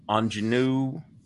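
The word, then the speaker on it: Aunjanue: Anjanou Ellis? That's a guess. – Yes. – Or King Richard. Close. It's close to that. That's correct.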